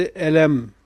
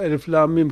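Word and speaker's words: Elif Lâm Mîm: Eğer kopuk olmasaydı elem, o elif lam mim kısmı elem okunurdu. Ondan sonrası saat ya da elmas diye okunurdu. Ama kopukluktan dolayı elif, lam, mim, sad.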